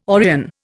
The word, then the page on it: Orion: Indian